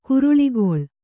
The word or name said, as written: ಕುರುಳಿಗೊಳ್